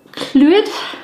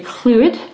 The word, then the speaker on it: Llwyd: For On This Day in Tudor History, the 21st of August 1568, Humphrey Clwyd, now it's spelt LLWYD, and the double L is really hard to say in Welsh. It's not really a cl sound, it's kind of softer than that, but I'm going to say Humphrey Clwyd.